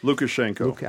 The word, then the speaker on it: Lukashenko: Lukashenko.